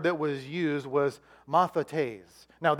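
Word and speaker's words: Mathetes (μαθητής): M-A-T-H-I-T-I-S. Is it up there? There you go. You got it. Mathetes is how you say it.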